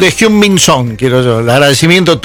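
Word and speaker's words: Heung-min Son: Pero te pido por favor que lo pronuncies bien como lo pronunció Manu Antuña. – Hyun Min Son. – Muy bien, fantástico.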